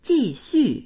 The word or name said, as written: ji4xu4